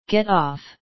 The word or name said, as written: get off